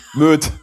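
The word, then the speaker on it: Moët: War das falsch ausgesprochen? Ich weiß es nicht. – Ja, nee, Moje. Wo wir wieder bei Sylt sind. – Möd. – Möd. – Möd. – Ich kaufe meine Flasche Möd.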